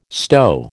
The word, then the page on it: stow: American English